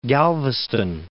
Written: Galveston

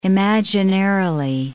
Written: imaginarily

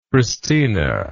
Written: Kristina

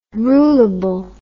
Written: rulable

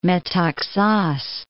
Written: Metaxas

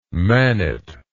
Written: manit

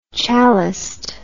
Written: chaliced

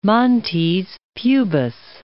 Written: montes pubis

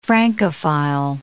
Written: Francophile

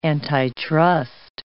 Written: antitrust

